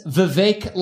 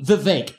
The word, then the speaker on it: Vivek: It's Vivek like cake. I'm not fake, and I swim in a lake of truth. That's me, Vivek. Yeah. – My name's Vivek. It rhymes with cake.